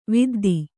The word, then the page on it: ವಿದ್ದೆ: vidde